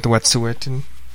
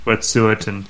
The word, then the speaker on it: Wet'suwet'en: Yeah, I just heard it on CBC a lot, and they seem to pronounce it Wet'suwet'en or Wet'suwet'en. I'm going with Wet'suwet'en. – Wet'suwet'en.